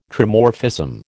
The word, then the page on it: Trimorphism: American English